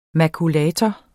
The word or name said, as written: makulator